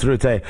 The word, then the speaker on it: Rutte: I know it should be pronounced Rutte, as you said it, because he does understand it.